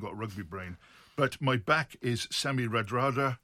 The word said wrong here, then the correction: Radradra: It's Semi Randradra.